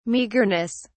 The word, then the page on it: meagreness: British English